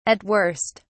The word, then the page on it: at worst: British English